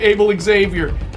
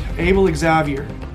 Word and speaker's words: Abel Xavier: Mason Holgate is joint fifth with Abel Xavier. How do you say that right way, guys? – Abel Xavier.